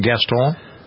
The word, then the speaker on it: Gaston: Is that how you pronounce the name, Gaston? – Mm-hmm.